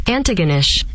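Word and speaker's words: Antigonish: But yeah, am I not saying it right? Anaganish? – The way that it says on here, I got the pronouncer up. Let's go with this. – Antaganish. – Antaganish. – I didn't think you said the T. – Oh, you weren't saying the T.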